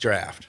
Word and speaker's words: draught: But is it that weird for someone not to know that D-R-A-U, and be honest, D-R-A-U-G-H-T, is it that weird for someone to think that that's not pronounced draft?